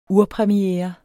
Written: urpremiere

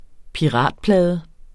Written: piratplade

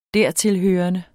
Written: dertilhørende

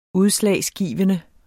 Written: udslagsgivende

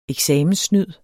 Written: eksamenssnyd

